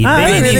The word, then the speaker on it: beninesi: Beninesi. L'hai detto bene alla prima, vedi? – Beninesi.